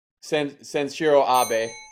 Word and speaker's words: Sanshiro Abe: Of course, she plays 4-3-2-1 at the D1 tournament. Name the other wrestler who also plays 4-3-2-1. – Shanshuri Abe, unless I'm saying that wrong. Penn State. – That's Sanshiro Abe.